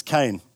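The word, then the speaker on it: Kain: And just a quick disclaimer, my family surname is Cain, but it's spelled with a K, not a C.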